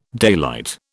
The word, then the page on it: daylight: British English